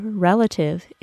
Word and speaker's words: relative: The first syllable of the word relative is stressed, and it is pronounced as re, with a short E sound, re.